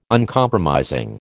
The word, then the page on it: Uncompromising: American English